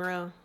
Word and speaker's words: Rheault: Victoria Marie Rowe. – Yeah. – It's your maiden name. – Maiden name Rowe. Yeah. – Mm-hmm. Spell it. – R-H-E-A-U-L-T.